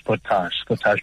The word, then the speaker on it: Sportage: Is it Sportage or Sportage, or is it just, am I pronouncing it right? – I think you're right, King Bash. Sportage. But if you want to be fancy, you can call it Sportage or whatever you say.